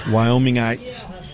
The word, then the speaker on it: Wyomingites: I met some native Wyoming, how do you say that? – Wyomingites